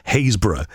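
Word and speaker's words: Happisburgh: So that should have been, and the pronunciation I've just checked it is Haysborough.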